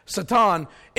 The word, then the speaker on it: Satan: The word Satan, and that's how it's pronounced, Satan, is a Middle Eastern word that means enemy.